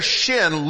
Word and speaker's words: shin: It can either be a s as in sin, or it can be a sh as in shin.